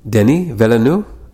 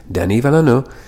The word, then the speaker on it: Denis Villeneuve: And I believe I'm pronouncing that right because I just heard an interview with him on YouTube, and I'm not trying to be a pretentious fuck who's trying to like make up the pronunciation of some guy's name. He's Canadian. He's like French-Canadian, so it's Denis Villeneuve.